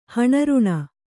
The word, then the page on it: ಹಣರುಣ: kannada